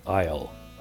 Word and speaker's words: aisle: For example, a silent S was inserted in aisle.